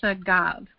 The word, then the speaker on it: sagav: And it's the Hebrew word sagav, and it's spelled S, as in Sam, A-G-A-V.